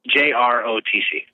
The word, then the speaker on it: JROTC: So JROTC, am I saying that right? Or is it JRROTC? – JROTC.